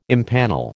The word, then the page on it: impanel: American English